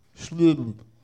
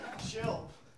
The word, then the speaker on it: Schilb: A A R O N underscore Shilb. – How do you spell it? – S C H I L B. – That's correct.